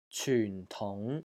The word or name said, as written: CYUN4 TUNG2